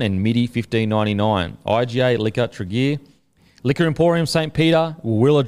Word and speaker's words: Tregear: IGA Liquor Trigger, so that's T-R-E, Trigger, Trigger, maybe. So head into IGA Liquor Trigger. It's spelled T-R-E-G-E-A-R. You boys know it, how to say that? – No, sorry.